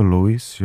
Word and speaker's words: Lewis: Luis Capaldi na vlnách Rádia Blaník. Řeknu vám to křestní jméno Luis, to je stejně zvláštní. Ono se to píše Lewis s dvojitým V a čte se to Luis, jo, Luis Hamilton, to všichni znáte, závodník Formule 1, Luis Capaldi.